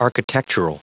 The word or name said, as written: architectural